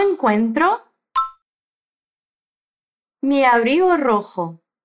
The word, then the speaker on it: rojo: A primeira sílaba é RO, é RO. Esse R do início da palavra é bem vibrante. Tenta. RO. E a segunda sílaba é com J, J-O, ou seja, rasga a garganta. RO. Vai. RO. RO jo, rojo, abrigo rojo. Assim é.